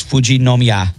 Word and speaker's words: Fujinomiya: 48-year-old man abandoned the 7-year-old at roughly 8,170 feet on the Fujimoyama Trail. I probably butchered that and I Fujiyama. – Apologize. No, it's Fujinomiya.